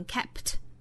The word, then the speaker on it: kept: kept 中间包含的这个 p 的发音，后面也遇到了一个爆破音，这个时候发音的方法是失去爆破，我们不能读成 kept，也不能直接把 p 的音略掉读成 kept，要注意上下嘴唇眯一下闭合，形成一个 p 的口型，但是不摆出后面的爆破的动作，不是 kept，而是 kept kept kept，这就是把前面的 p 的发音做失去爆破的发音方法。